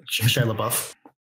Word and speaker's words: Shia LaBeouf: Shia LaBeouf.